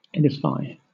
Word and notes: edify: British English

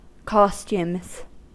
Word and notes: costumes: American English

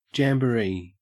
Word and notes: jamboree: Australian English

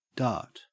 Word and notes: dart: Australian English

